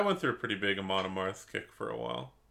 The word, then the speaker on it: Amon Amarth: So Vlad Bad is going with Amon Amarth. Am I saying that right? – Amon Amarth.